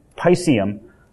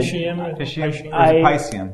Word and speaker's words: Piscium: but not as much of a problem as GU Piscium. That's how you pronounce it. – Piscium. Because it's like Pisces.